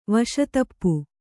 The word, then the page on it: ವಶತಪ್ಪು: kannada